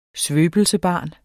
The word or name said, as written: svøbelsesbarn